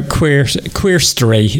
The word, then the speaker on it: QueerStory: Yeah, it's a brand new series about QueerStory is how we pronounce it, rather than QueerStory, because it is a mix of queer and history.